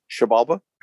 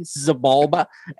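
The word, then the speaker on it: Xibalba: I've always said it the white boy way, Zabalba, Exabalba. – Yeah. – Yeah, I've always called it Zabalba, but I've been friends with you guys for so long that I figure I need to start saying it right. So Shabalba. – Yeah, Shabalba's the I guess the way it's pronounced.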